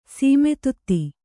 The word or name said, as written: ಸೀಮಿತತೆ